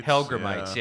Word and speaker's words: hellgrammites: Hellgrammites. – Hellgrammites, yeah.